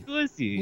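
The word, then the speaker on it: Qissi: It's Q-I-S-S-I. How would you say that? Kusi? Kusi? – Kusi. – Kusi.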